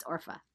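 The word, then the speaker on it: Orpah: I mispronounce her name sometimes. Orpha, I think. Because Oprah's Oprah and it's Orpha.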